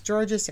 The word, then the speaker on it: Georgas: My last name is Georges. It rhymes with gorgeous.